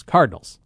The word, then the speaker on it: Cardinals: Is it a two-syllable or a three-syllable word? Because I've heard it said both ways within the last 10 minutes by people who broadcast for a living. Cardinals? I've always kind of said it two syllables. Cardinals. – Yeah, Cardinals. Kind of like Wednesday.